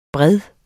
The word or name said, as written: bred